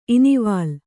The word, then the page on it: ಇನಿವಾಲ್: inivāl